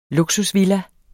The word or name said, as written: luksusvilla